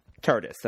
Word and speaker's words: TARDIS: Now, am I pronouncing this right? TARDIS? – TARDIS, that's TARDIS.